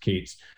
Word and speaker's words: Katz: in the and the thing of daryl katz the uh or kate's sorry pronounced kate's